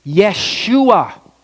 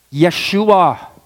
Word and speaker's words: Yeshua: What's significant is that Joshua, okay, you know the Hebrew pronunciation for the word for the name Joshua? Yeshua. Yeshua. Joshua. Yeshua.